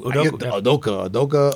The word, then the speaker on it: Udoka: Udoku? – Udoka.